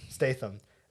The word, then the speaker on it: Statham: Statham.